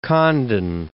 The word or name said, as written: Condon